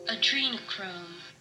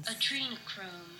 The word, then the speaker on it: Adrenochrome: Adrenochrome. – I've been Adrenochrome.